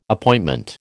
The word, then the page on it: Appointment: American English